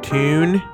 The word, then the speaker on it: town: Toon? – Yeah. – Interesting, huh? – Really? That's why they call it the Toon Army? – The Geordie pronunciation of town.